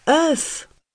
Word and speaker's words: œuf: Je mis plusieurs secondes à recoller les morceaux dans ma tête et m'exclamai œuf Il ouvrit les yeux comme pour dire Voilà On prononce œuf, enchaînai-je. œuf ouh. Non. Regardez ma bouche. Il faut l'ouvrir davantage.